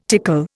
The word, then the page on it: tickle: Indian